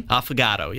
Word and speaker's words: Affogato: Is it Affogato? – Affogato, yeah. – Affogato. – Affogato, yeah, you got to accentuate it like that.